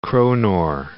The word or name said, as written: kronor